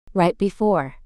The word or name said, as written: right before